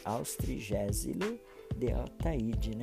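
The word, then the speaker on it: Austregésilo de Athayde: No encontro com o mestre dessa edição, o presidente Queda faz referência ao Dr. Austrégilus de Ataíde, um nome difícil de pronunciar, mas é, isso aí, Austrigésilo de Ataíde, né?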